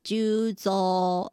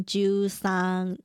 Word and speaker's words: 十三: 那木木警官他的日文发音呢就叫做 Megure Juzo 而不是十三 Juzan，在名字里面呢会念作 Juzo。